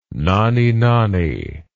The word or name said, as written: nonny-nonny